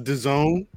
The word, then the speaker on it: DAZN: It's pronounced DAZN. – Yeah, you wouldn't you couldn't tell based off how it looks, but yeah.